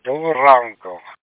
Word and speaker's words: Доброго ранку: Доброго ранку. – Доброго ранку? – Pretty close. I just can say, not rrr. – Доброго ранку.